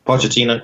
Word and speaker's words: Pochettino: Pochettino, yeah.